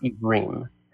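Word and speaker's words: Ivrim (עברים): So in Hebrew, pronounced Ivrim.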